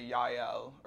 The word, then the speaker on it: Yael: It's like, yeah, and it's, so it's like, it's Hebrew, but they say it, they usually spell it with a Y instead of a J, and then they say Yael, or even if it's with a J, you still say, like, you, how they say Yahweh, kind of thing.